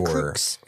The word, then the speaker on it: LaCroix: I think it's Lacroix. – Lacroix? – Pretty sure. – Lacroix. – Pretty sure. Lacroix. Pretty confident in that.